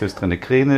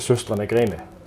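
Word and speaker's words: Søstrene Grene: Und vielleicht kannst du das Mysterium mal lösen, wie wird das richtig ausgesprochen? Viele sagen, es ist Höchstrinne Kräne, ist das richtig so oder wie sagt das an denen? – Ja, richtig? Äh, Schüstrene Gräne.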